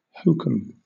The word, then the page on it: hokum: British English